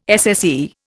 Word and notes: SSE: Indian

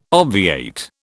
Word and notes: obviate: British English

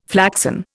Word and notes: flaxen: Indian

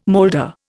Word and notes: molder: Indian

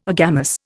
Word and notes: agamous: Indian

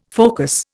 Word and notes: focus: Indian

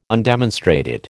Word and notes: undemonstrated: American English

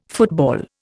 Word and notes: football: Indian

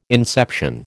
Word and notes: inception: American English

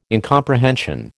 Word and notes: incomprehension: American English

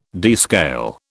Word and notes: descale: British English

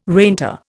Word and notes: reinter: Indian

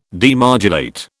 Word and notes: demodulate: British English

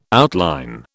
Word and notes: outline: British English

